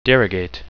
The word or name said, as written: derogate